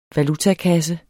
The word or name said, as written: valutakasse